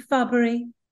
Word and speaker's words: fubbery: Fubbery means a deception,